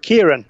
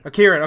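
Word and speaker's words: Ciarán: How do you pronounce this? C-I-A-R-A-N. I know it's a... – Kieran. – Kieran. Okay, it is Kieran.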